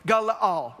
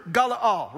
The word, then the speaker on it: galal: But the word is G-A-L-A-L, it's Galaal, right? There's like three syllables, even though it only looks like two.